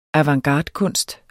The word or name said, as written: avantgardekunst